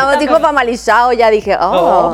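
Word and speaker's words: Famalicão: El famalizado. – Famalizado. – Sí. Porque no se dice famalicao.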